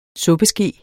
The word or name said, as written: suppeske